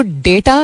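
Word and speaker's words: data: तो डेटा या डाटा, however you want to pronounce it, because Pakistan में ज़्यादातर लोग डाटा बोलते हैं, लव्स डेटा होता है, बट इट्स ओके अगर डाटा भी बोलते हैं, तो जूते की कंपनी है ना, उससे मिलता जुलता.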